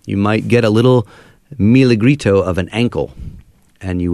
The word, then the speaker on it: Milagrito: Is that how you say it? – Yeah, Milagrito. Yeah. – Milagrito. – Milagrito is a Spanish word that means little miracle.